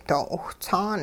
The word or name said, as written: doo 'iwhts'aan